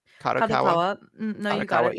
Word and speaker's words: Kadokawa: Am I butchering that? – Kadokawa. – Yeah, okay.